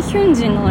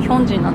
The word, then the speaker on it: Hyunjin: ですけどなんだろうなそうで直近そのストレイキッズのヒョンジンえやばヒョンジンで合ってるよねなんかもうなんかアルファベット表記だと明らかにヒュンジンなのになぜか読み方はヒョンジンなんだよね